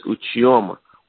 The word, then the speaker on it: Uchioma: My name is Uchioma Mabara.